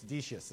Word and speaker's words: Decius: It's Decius, is how we, we call this guy.